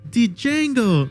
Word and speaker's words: Django: Well, We have Walton Goggins' character telling Django, "Django, you black son of a bitch." And then Django tells him, "The D is silent, hillbilly."